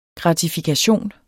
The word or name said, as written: gratifikation